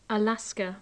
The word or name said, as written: alaska